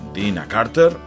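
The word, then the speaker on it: Deana Carter: És un artista com la Diane Carter, Dina Carter, es fa dir ella, que es pronuncia Dina Carter, en Dixon County.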